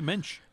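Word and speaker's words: Moench: But I see these dollar books at the comic shop in Oswego, New York, and they were the original Bill Sienkiewicz, Doug Miach. Your prices and participation may vary on the pronunciation of that name, ladies and gentlemen. – Could be Minch. – That too. Probably that's the correct one.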